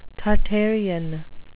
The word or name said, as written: Tartarean